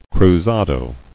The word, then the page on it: Cruzado: cru·za·do